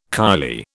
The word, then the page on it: kylie: British English